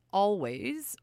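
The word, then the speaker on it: Alvvays: And before that, out of the Canadian Maritimes, we heard Always, or if you want to pronounce it, Always. So it's A-L-V-V-A-Y-S with their very dreamy song, Dreams Tonight, from their record released just over a year ago called Antisocialites.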